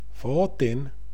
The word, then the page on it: fatin: svenska